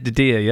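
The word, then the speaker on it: DiDia: And it was produced by Nick Dedia, who, did I pronounce that right? Dedia? – Yeah, that's it. Dedia. Yep, you got it.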